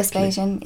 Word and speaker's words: Vespasian: Let's go to your first scene, which is in June 79 AD, with the death of Vespasian. Am I pronouncing that correctly? – Vespasian, yeah.